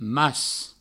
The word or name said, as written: mas